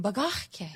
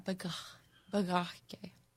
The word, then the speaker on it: bagahke: We like that one, bagahke. – Yeah. – But it's really, emphasize on that, bagahke.